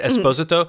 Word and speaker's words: Esposito: I think it's Esposito. Mm-hmm. It's basically just the emphasis is on the second syllable, not the third.